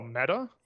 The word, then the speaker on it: Meta: Is it meter or is it meter? – What is it? Oh, Meta? – Yeah, I say meta. Do you say meta? – I would say Meta, yeah.